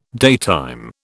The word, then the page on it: daytime: British English